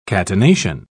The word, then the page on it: catenation: British English